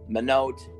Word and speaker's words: Minot: people pronounce it Minot because it's spelled M-I-N-O-T.